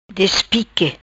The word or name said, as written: Despique